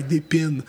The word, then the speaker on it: Des Pins: Or, sorry, Des Pins? – Yeah. – That's funny, because that just sounds like the bread. Pain? – Well, it's... – It's the same pronunciation. – P-I-N-S.